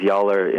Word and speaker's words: Joller: And one of the scientists who's looked at biostress numerous times, Peter Yawler, it's pronounced Yawler in German, but it's spelled J-O-L-L-E-R.